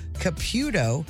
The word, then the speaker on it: Caputo: And Chris has Dan from Arnold up for grabs, a pair of tickets to see the Long Island Medium, Teresa Caputo. Didn't know that's how you pronounced her name.